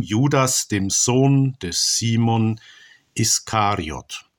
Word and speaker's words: Iskariot: In unserer lutherischen Tradition wird er oftmals auch Ischariot ausgesprochen. Aber das hängt damit zusammen, dass ein deutsches SCH ein Sch ist, aber wenn es aus dem Griechischen kommt, ist ein Sigma und ein Chi, das deutsch wie ein SCH übersetzt wird, doch nur ein SK, Iskariot.